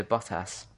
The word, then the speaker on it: Bottas: How do you say it? – I say Bottas. – Bottas? Yeah, because you guys... – It's just bot with ass. – It's botass. – Well, no, because it's BOTTAS. Botass.